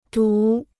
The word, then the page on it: 读: dòu